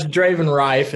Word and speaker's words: Draven Riffe: Pretty much Draven Riffe.